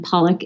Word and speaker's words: Pollak: It's L I N D S E Y, and Pollak is P O L L A K.